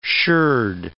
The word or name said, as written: sherd